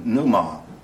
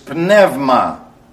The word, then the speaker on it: pneuma: Or if you prefer that silly Bible school pronunciation, pneuma. All the letters in the Greek language in a sentence and in a word are pronounced. Okay, we say psalm, they say psalma. Okay? It's pneuma, not pneuma. Just thought I'd get you to understand that.